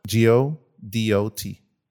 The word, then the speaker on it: Godot: G O D O T.